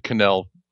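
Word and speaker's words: Knull: Or Null, however you pronounce it. I pronounce it Kanul, well, for reasons that any Swedish speaker will tell you.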